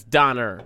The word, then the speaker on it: Donner: Dauber party? – Mm. It's Donner.